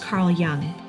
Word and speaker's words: Jung: Oh, by the way, as a brief segue here, I said Carl Jung last week, and it's pronounced Carl Jung.